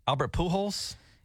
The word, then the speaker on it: Albert Pujols: Albert Pujols. – Yeah, he hit his 7th home run. He's the 4th player to hit seven hundred home runs. Yes. Okay. Here's the moment it happened. – Albert Pujols homered earlier tonight.